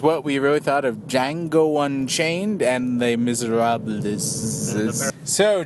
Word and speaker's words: Django: Django. Unchained. – The Django. – It's the D is silent.